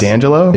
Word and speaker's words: D'Angelo: It's not D'Angelo? – It's Michael Eugene Archer.